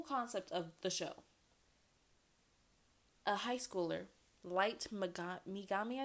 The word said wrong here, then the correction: Yagami: Light Yagami, not Bigami, Yagami, Light Yagami.